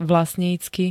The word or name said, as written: vlastnícky